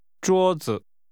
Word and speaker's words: 桌子: This is pronounced with a high-level tone, followed by a neutral tone. – 桌子。